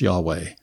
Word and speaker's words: Yahweh: And for a long time, we dealt with Jehovah. Even Christadelphians will find Jehovah in a lot of their language. But as people got to know more, they got to realize that it really should be pronounced Yahweh.